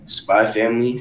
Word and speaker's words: Spy x Family: Nah, but y'all know it's pronounced spy family, because it's like Hunter. It's like spy family.